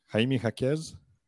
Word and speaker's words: Jaime Jaquez: Jaime Jaquez? – Right on the money.